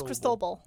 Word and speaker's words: Cristobal: Was it Cristobal or Cristobal? – No, it was Cristobal. – Okay, yeah. – It's spelled like Cristobal.